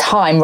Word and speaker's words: Haim: Yeah, I've just seen another one that says Haim rhymes with time.